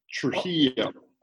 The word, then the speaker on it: Trejo: The best way to think about it is pronounce the J like an H, Trejo.